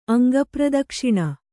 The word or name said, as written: ಅಂಗಪ್ರದಕ್ಷಿಣೆ